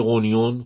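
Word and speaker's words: Rognon: روگنون نوشته میشه ولی غونیون خونده میشه. روگنون، فردریک روگنون که فردریک غونیون در واقع تلفظ میشه.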